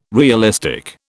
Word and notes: realistic: British English

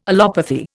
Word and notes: allopathy: Indian

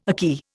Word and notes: akee: Indian

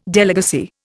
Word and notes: delegacy: Indian

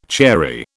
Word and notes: chary: British English

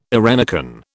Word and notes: eirenicon: British English

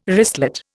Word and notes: wristlet: Indian

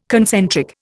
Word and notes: concentric: Indian

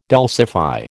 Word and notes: dulcify: American English

